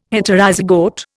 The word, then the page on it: Heterozygote: Indian